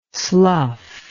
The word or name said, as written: sluff